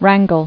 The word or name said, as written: Wrangling